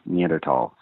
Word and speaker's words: Neanderthal: I am author of the Darth Vader and Son series, Jedi Academy series, and most recently the Lucy and Andy Neanderthal series. – I like that you pronounced it Neanderthal, which I guess is the proper pronunciation.